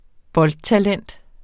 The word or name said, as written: boldtalent